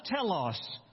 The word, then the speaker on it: telos: And this word in the Greek is pronounced telos. We would use the English letters T-E-L-O-S. To spell this, telos.